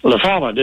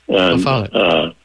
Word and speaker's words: La Follette: I pronounce it the way my mother did, Midwestern. – Okay, and, La Follette. That's good. – Right. I mean, they didn't know French, so they didn't get it right.